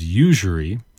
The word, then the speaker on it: usury: All right, let's move on to our next word, which is usury. It's got kind of a weird pronunciation when you look at it. Usury, spelled U-S-U-R-Y. Usury. This one's probably not phonetic.